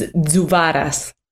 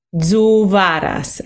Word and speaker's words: Zuvaras: Last name is Zuvaras. – Say that again. – Zuvaras. It's like a Zuvara. – Yeah, exactly. Zuvaras.